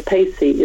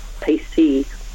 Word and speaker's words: Pacie: Or if you do want to look me up, I just would like to say my surname, Pacey, is spelled P-A-C-I-E, which people sometimes don't realize. So Grace Pacey with an I-E.